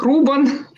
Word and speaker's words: Рубан: Фамилия моя читается как Рубан.